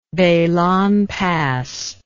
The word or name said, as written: beilan pass